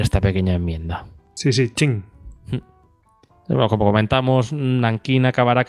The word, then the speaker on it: Qing: Pues aunque parece escrito como King, eh, al final he escuchado otros podcasts así y pronuncian como Ching.